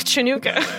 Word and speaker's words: Chanukah: It's Hanukkah. – Oh. – Chanukah. – You gotta pronounce the it's Chanukah.